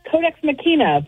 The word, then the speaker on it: Codex Machina: How am I pronouncing that, Heather? Pretty good? – That's Codex Machina, but I'll take it either way.